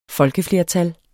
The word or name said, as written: folkeflertal